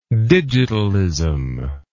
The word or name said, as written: digitalism